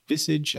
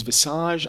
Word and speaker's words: visage: Ooh, visage, I think is how it's pronounced. Let's see. – Visage. – Visage. Visage. Oh, there's a Z in there that I hadn't anticipated.